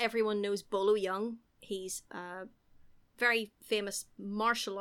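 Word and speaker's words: Bolo Yeung: It is Bolo Young.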